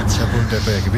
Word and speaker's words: Chapultepec: Chapultepec. Mejor que algún cementerio francés. – Que... Chapultepec, bien.